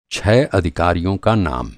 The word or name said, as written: अधिकारी